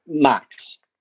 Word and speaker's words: Max: Max.